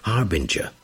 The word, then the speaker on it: harbinger: Harbinger. H-A-R-B-I-N-G-E-R. So it's a J, not a G, not a hard G, a soft G. So harbinger. A person or thing that announces or signals the approach of something else.